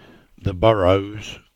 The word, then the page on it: Burrows, The: English